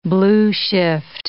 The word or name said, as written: blueshift